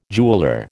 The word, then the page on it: jeweller: American English